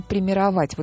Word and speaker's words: премировать: Да, я только единственное, что хочу сказать, что хотим мы премировать, вот именно так надо ставить ударение, Алёшу, чей телефон начинается с 612, потому что он прислал вариантов 10, и все хороши.